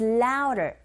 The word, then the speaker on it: louder: 근데 드 발음이 안 들리죠. 우어라는 모음 사이에 드 사운드가 꼈어요. 역시 트 사운드처럼 이 강몸과 양몸 사이에 드 사운드 역시 R 소리에 가깝게 들리게 되는 현상이죠. So louder, 틀린 발음이 아니라 원어민의 입에서 나오는 소리 자체가 louder 이렇게 들리거든요. 그렇게 발음해 주시면 됩니다.